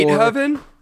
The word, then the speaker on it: Beethoven: Beethoven? – Yeah, Beethoven. Dang it, I should have pronounced it that way.